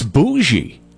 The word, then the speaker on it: bougie: It's bougie. Now, you would probably think that that is a B-O-S-H-I-E, or maybe even a bushy, a B-U-S-H-I-E, right? No. It's spelled B-O-U-G-I-E.